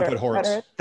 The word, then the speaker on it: Podhoretz: John Podhoretz.